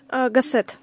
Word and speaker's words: Гассет: Вот правильно скажу ударение: Ортега и Гассет? – Гассет. – Гассет. Ортега и Гассет.